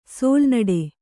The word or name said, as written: ಸೋಲ್ನಡೆ